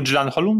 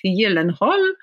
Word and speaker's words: Gyllenhaal: jak powinno się teoretycznie wymawiać jego nazwisko, gdyby wymawiać je zgodnie z zasadami oryginalnej pisowni i byłby to Jake Gyllenhaal, więc tak.